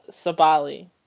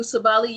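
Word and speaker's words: Sabally: I had Sabali. I'm pretty sure I said that right. – Satu Sabali, you look. – Yeah, I said it right. – You got it right.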